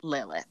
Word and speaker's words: Lilith: Her name is Lilith, and it is pronounced Lil-ith.